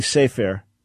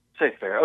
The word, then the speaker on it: Cepher: It's spelled as C-E-P-H-E-R. – Presumably Sefer. Yeah, but go ahead. – Sefer. Okay, yeah. I figured you would get it right.